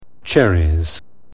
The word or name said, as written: cherries